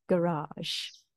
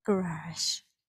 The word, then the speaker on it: garage: and in America, garage. – Garage. – So again, in the UK, the stress is on the first sound, garage. – Garage. – And in America, it's on the second sound, garage.